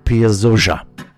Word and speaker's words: Piazzolla: And when I said Astor's name before, I always forget the double L in Argentina is pronounced as a Z. So the other Z's in his name is pronounced as an S, so it's actually Astor Piazzolla.